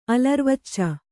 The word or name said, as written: ಅಲರ್ವಚ್ಚ